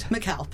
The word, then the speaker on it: Mikhail: Mikhail, but close. – Mikhail. – You have to put the accent on the right syllable.